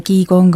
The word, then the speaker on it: qigong: Mõned ütlevad selle kohta qigong, aga mõned ütlevad qigun. – Qigun? – Jaa. – Kuidas õigem on siis? – Mina tavast ütlen qigun. – Qigun. – Aga ma olen kuulnud erinevaid hääldusi.